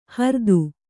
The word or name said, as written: ಹ್ರೀತ